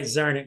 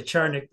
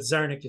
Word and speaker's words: Czarnik: Right, Czarnik. Yeah, some people say Czarnik, but Czarnik is the way I use it, right? – Welcome, as usual, to Politics Done Right, Tom.